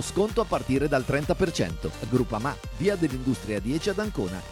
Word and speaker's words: Groupama: Biancorosso Groupama, ah no, si pronuncia Groupama, di Paolo Felicetti.